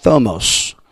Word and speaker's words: thumos: Almost sounds like thermonuclear. It sounds bad, don't it? Thermonuclear sounds terrible. But thomos, thumos, but it's pronounced thomos.